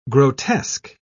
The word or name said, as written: grotesque